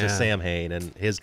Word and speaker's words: Samhain: You know, he's talking about Samhain or whatever. That's how, you know, everybody pronounces it Samhain, and I think he says Samhain, or I guess that's the correct pronunciation, but...